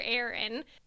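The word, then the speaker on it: Aron: So in this segment, we ask you to answer a question from Dr. Arthur Ahrens. I have been informed that I've been saying his name incorrectly for this entire podcast. So sorry about that, Dr. Arthur Aaron.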